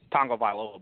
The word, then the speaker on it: Tagovailoa: Tagovailoa, by the way, is how you pronounce that.